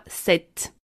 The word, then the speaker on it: cet: En revanche, on ajoutera un « t » à « ce » et on le prononcera « cette ». C-E-T. On dira « cet acteur »,« cet aéroport »,« cet arrangement », »,« cet appartement ».